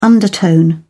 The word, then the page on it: undertone: British English